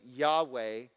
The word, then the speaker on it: Yahweh: We see, A, this would be our first subpoint, the presence of Yahweh. And when I say Yahweh, you spell it Y-H-W-H. Y-H-W-H. Remember, in the Hebrew language, there are no there's no vowels written in. So it's Yahweh, and this is the special covenantal name of the Lord.